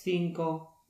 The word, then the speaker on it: cinco: Natomiast C przed E, I wymawia się jak C, podobne jak w angielskim wyrazie think. Na przykład cinco, Barcelona.